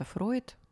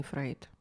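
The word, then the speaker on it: Freud: Он вообще-то Фройд, а не Фрейд.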